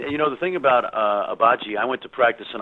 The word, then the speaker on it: Agbaji: Yeah, Ochai Agbaji. G-silent, Agbaji, yep.